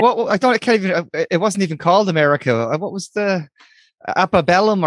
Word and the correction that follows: Antebellum: Antebellum. – Antebellum.